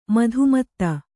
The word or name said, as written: ಮಧುಮತ್ತೆ